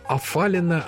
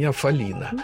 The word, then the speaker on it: афалина: Есть профессиональные, потому что все, кто занимается зубатыми китами, почему-то один из видов дельфинов называют «афалина», а не «афалина».